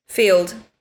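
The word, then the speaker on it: Feeld: It's, I don't know how to pronounce this, but it's F-E-E-L-D. Is that Field? – Field.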